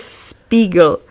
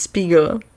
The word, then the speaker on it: Spiegel: Pan Charles Spiegel. – Spiegel. A on se byl pravděpodobně byl německýho původu a jmenoval se nomáje Spiegel, což znamená zrcadlo. A američani to neumějí vyslovit správně a říkají mu Spiegel.